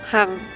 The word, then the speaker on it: Hang: My Vietnamese name Kalik, difficult to pronounce, Hang, H-A-N-G, so I change to Shirley.